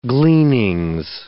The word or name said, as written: gleanings